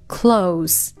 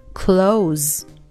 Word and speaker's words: close: 这个词作为形容词，也就是紧密的、亲密的这个意思的时候呢，它要读成清辅音 close。如果呢，它是作为关闭的这个动词的时候呢，我们要把它读成浊辅音 close。